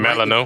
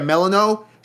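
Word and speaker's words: Melinoë: You play as, I hope I pronounced the name Melano. – Right. Yeah, Melano.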